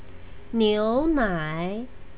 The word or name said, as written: niunai